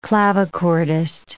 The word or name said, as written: clavichordist